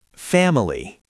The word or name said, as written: family